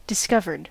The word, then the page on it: discovered: American English; [dɪ.ˈskʌv.ɚd]; /dɪ.ˈskʌv.əd/